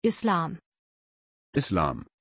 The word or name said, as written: islam